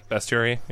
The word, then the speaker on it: Bestiary: Bestiary, yeah. – Yeah, Bestiary, whatever. – Oh, yeah. – Throw a little Polish pronunciation on there. So there's only an E. It's B-E-S-T.